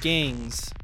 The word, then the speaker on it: Gayngs: It's pronounced Gangs, but it's spelled G-A-Y-N-G-S, like gay.